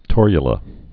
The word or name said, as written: torula yeast